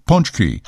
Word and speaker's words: pączki: Okay, I'm sure we all know the word spelled P-A-C-Z-K-I is magically pronounced punchki.